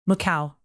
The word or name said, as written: macau